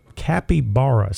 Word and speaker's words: capybara: The world's largest rodent called a capybara. – Oh, yeah. – Is that how you pronounce it? – Yeah, I'm familiar with this animal.